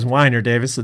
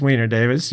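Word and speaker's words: Weiner-Davis: What I thought it was Wiener Davis at this time, but it was Wiener Davis.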